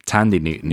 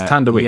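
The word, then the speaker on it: Thandiwe: she was known as Tandy Newton. Yeah. But because it's Tandy, I think the H is silent. – Oh, is it Tandy? Oh, so it's Tandaway. – Yeah, I think it's Tandaway.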